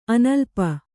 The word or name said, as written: ಅನುಲೇಪ